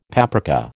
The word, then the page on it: paprika: American English